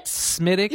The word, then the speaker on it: Smithwick's: Like S-E-A-M-U-S, Seamus. – Mm-hmm. – S-M-I-T-H-W-I-C-K-S. – Smithwicks? – Smithwicks is pronounced... – Smittix! You're kidding me! – They're like, well, that's not how you pronounce it. It's pronounced Smittix.